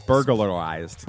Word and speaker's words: burglarized: Burglarized. – No. – It I don't know. Somehow, I don't know. Oh, you're adding another syllable in there. Burglarized.